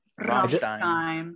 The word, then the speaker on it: Rammstein: So, Rammstein, right? Mm-hmm. That's how you say it. Is it Stein? Close enough. – Stein, yeah. – Rammstein.